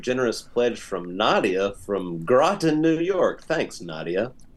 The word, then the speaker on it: Groton: I live in the town Groton in New York. I think it was pronounced Groton. – Yeah, I pronounced it Groton because I was thinking I was thinking of Croton.